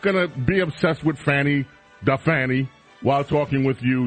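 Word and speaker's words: Fani: I have heard recently the pronunciation is Fanny, but that was never the pronunciation until you started doing your bit on the Fanny.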